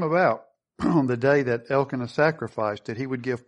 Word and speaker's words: Elkanah: Elkanah, I guess, is really how you say it.